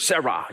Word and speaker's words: Serah: It's the same word, Sarah. You might spell it in English, S-E-R-A-H.